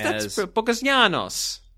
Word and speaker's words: Bogazianos: Vasily Bogazianos. – I think that's Bogazianos. – Again, it's Greek, not Italian.